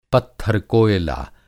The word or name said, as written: पत्थर-कोयला